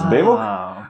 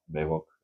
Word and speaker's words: Bevog: And that basically means that you can say it like Bevog, Bevog, Bevog, whatever. There's not a right or wrong way to say it, but we normally go Bevog.